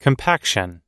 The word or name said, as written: compaction